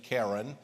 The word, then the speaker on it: Karen: It's spelled as if it's K-A-R-E-N, Karen, my sister's middle name. And but it's pronounced the Karen people.